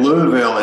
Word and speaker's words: Louisville: So, yeah, that Louisville, it's interesting. It's almost like the accent is on the first part of the word, the Lou.